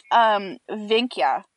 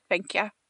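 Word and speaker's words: Wenche: And her name is, it's spelled Wench. – No, it's pronounced Vinkya. – Girl, I am proud of you. – Yeah, Vinkya.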